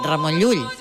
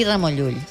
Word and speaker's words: Ramon Llull: Bueno, y yo lo entiendo, porque pronunciar Llull, si no eres valenciano, balear, catalán, no se te da bien. – Difícil. – A ver, di Ramon Llull. – Ramon Llull.